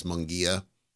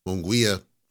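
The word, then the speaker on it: Munguía: And I know it's, you know, officially, if you're pronouncing it, it's Munguia, because I'm Spanish, but Munguia flows better off the tongue, so I'm going to go with that.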